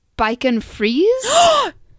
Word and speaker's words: Bichon Frise: It's called, I'm going to butcher this, but it's called I want to say Bichon Frise? – Yes.